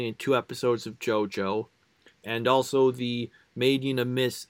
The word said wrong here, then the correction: Made in Abyss: I keep mispronouncing this episode. I don't know why. I said Made in a Miss. Made in Abyss...